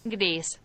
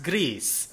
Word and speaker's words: Gris: I mean, I think it's pronounced Gris. – It's pronounced Gris according to Google Translate.